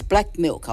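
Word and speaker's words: BLK MLK: It's Black Milk. I'll say Black Milk throughout the article because it's spelled BLK space MLK.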